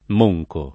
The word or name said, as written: monco